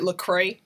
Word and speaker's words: Lecrae: Is it Lecrae? Am I saying that right? – Yeah.